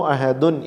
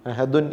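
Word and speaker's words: അഹദ് (أحد): ഖുൽ ഹു അല്ലാഹു അഹദുൻ എന്ന ദാലിന് ശേഷം ഒരു നൂനിനെ നമ്മൾ ഉച്ചരിക്കുന്നില്ല വഖഫിൽ. അഹദുൻ എന്ന് പറയാറില്ല, ആ നൂനിനെ നമ്മൾ ഒഴിവാക്കുകയാണ്. അഹദ് എന്നാണ് പറയുന്നത്.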